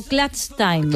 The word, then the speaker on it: clutch time: Y luego, la última clave que venía a traer es el, digamos, como llaman los americanos en la NBA, el club. – Esto me ha encantado. – ¿No? – ¿Clutch time o clutch time? Clutch time, se debería pronunciar, perdón.